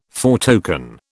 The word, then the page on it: foretoken: British English